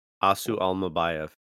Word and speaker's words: Asu Almabayev: Asu Almabayev.